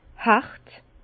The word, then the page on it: hert: Bas-Rhin; Herrlisheim